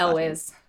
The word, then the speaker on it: Elwes: Elwes.